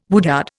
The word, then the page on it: woodyard: Indian